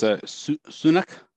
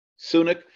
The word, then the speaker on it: Sunak: I guess it's Sunak. – Sunak? – Yeah.